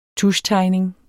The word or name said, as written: tuschtegning